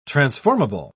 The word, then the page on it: transformable: British English